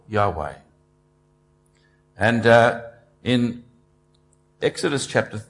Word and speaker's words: Yahweh: It's Y-H-W-H in the Hebrew language, and we're not even completely sure how to pronounce it. It tends to be pronounced Jehovah in a lot of the older versions of the Bible, so if you read it, a King James version, you'll see the word Jehovah there. But we're not sure that that's how it is meant to be pronounced. In fact, scholars in more recent times think it probably should be pronounced Yahweh. Yahweh.